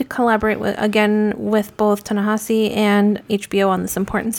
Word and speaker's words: Ta-Nehisi: Ta-Nehisi Coates. You're not saying it right either. – Yeah. – There's not two N's in there. – Ta-Nehisi. Sometimes I say it fast enough.